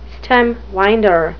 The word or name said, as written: stemwinder